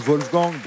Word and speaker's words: Wolfgang: Oui, Wolfgang, on prononce Wolfgang, parce que c'était de langue allemande, mais c'est bon.